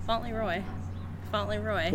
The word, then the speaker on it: Fauntleroy: If you were to catch the ferry from not Fauntleroy, what is Fauntleroy? – Mm-hmm. What'd you say? – West Seattle, Fauntleroy. – No. What'd you say? – Fauntleroy. – Like Leroy. – Fauntleroy. – Like Leroy Jenkins.